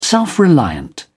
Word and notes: self-reliant: British English

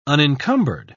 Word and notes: unincumbered: British English

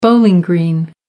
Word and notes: bowling green: American English

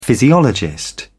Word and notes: physiologist: British English

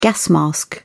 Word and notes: gas mask: British English

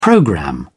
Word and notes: programme: British English

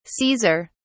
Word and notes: caesar: British English

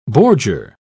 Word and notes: bordure: British English

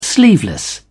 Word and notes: sleeveless: British English

